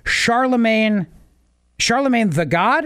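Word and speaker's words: Charlamagne tha God: So she does an interview with the Breakfast Club hosts DJ Envy and Charlemagne the God, is that right? Am yes.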